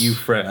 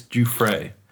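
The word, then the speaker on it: Giuffre: Gouffre. I think it's Gouffre.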